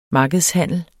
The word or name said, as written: markedshandel